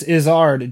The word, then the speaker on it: Izzard: Eddie Izzard? – Yes. – Yeah, he is a good comedian. He's a very good comedian. So. – It's not Izzard, dear. It's Izzard.